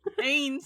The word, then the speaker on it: Ain: No, that means they'd be decanonizing, like, fucking Anne or fucking Dix. – Ain? – Ain, sorry. – You have to say it like you sound like you're in pain.